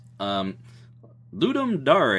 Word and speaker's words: Ludum Dare: Ludum Dare is how it's pronounced. I know it looks like Ludum Dare.